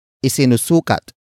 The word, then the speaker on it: isinusukat: Word for word it's isinusukat ni Cassandra ang traje de boda.